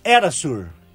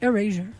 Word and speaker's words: Erasure: Pois é, já tocamos música da banda Erasur. Como é que fala isso? Fala pra nós. – Erasure.